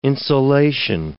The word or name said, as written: insolation